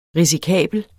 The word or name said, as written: risikabel